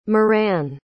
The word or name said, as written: muireann